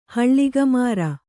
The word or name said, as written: ಹೊಳೆಗರ